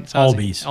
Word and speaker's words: Albies: It is pronounced. Did I get that one right? – Albies. Albies.